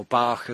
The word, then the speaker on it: Bach: Now, I guess several of you out there will probably have encountered, perhaps, say, the Bach flower references, or Bach, as some people say his name.